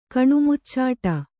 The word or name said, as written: ಕಣುಮುಚ್ಚಾಟ